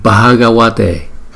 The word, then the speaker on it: Bhagavate: Bhagavate, chữ B, H thì chúng ta đọc như chữ B nhưng mà chúng ta đưa nhiều hơi từ trong miệng chúng ta ra. Ba, ba, Bhagavate, Bhagavate. Chữ V, chữ V này nè, chúng ta đọc là wa, wa, wa.